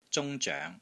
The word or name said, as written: ZUNG1 ZOENG2